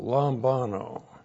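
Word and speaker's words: lambano (λαμβάνω): Looks like that in the Greek, L-A-M-B-A-N, long O, lambano.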